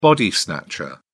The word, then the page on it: body snatcher: British English